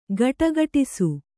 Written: ಘುಡುಘುಡಿಸು

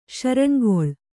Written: ಶರಣ್ಗೊಳ್

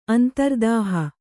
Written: ಅಂತರ್ದೇಹ

